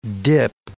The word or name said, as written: dip